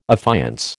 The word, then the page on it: affiance: American English